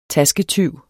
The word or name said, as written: tasketyv